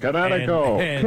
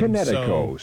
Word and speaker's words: Kinetico: Everybody knows that Connecticut is spelled K-I-N-E-T-I-C-O. – I thought the instructor said Connecticut. – You should have remembered Connecticut as in kinetic energy with an O on the end. Connecticut. – Connecticut. Spell it K-I-N-E-T-I-C-O.